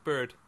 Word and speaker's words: beard: I found out it wouldn't have been pronounced beard; it would have been pronounced more like bird. – Bird. That's weird. – Bristly bird. So then it rhymes then with herd.